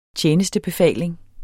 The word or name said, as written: tjenestebefaling